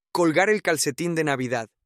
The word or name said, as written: colgar el calcetin de Navidad